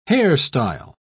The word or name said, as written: hairstyle